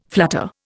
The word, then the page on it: flutter: Indian